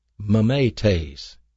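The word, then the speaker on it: Mimetes (μιμηταί): This is a Greek word called mimetes. Mimetes. M-I-M-E-T-E-S. And both of those E's are pronounced as an A.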